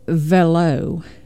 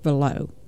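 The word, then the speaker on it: Veilleux: So, Jeanne Velo, and I might be saying this wrong, it's V-E-I-L-L-E-U-X, Velo. I'm going to go with Velo.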